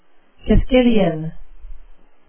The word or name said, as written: quisquilian